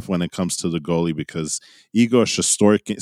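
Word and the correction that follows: Shesterkin: I believe it's Shestorkin.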